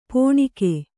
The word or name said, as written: ಪೋಣಿಕೆ